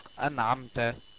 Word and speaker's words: أنعمت: ثالثا، مع العين زي كلمة أنعمت سورة الفاتحة، أنعمت النون ساكنة وجاء بعدها حرف العين فتنطق أنعمت، وحكمها الإظهار الحلقي.